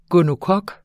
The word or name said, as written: gonokok